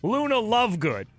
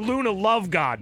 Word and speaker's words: Luna Lovegood: Oh, I pronounced the uh, girl's name wrong from Harry Potter, Luna Lovegood. I called her Luna Lovegod mistakenly because she's going to be on Dancing with the Stars this year.